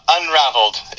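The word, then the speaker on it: Unravelled: Is it Unraveled or Unraveled? How do you say it? – Unraveled. It just has two L's because of... And that's the British spelling of the word.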